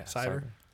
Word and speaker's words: Sciver: Yeah, Sciver. – I like Sciver because it rhymes with Scyther, my favorite Pokemon.